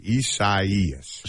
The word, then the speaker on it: Isaias: Isaias.